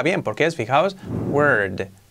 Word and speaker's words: word: Enseguida nos vamos a la R bien marcada, word. Hace un efecto casi, no es como una E, pero casi, parece word, word, word, word, word, word, word. Y luego tenemos que hacer la D al final, word, word.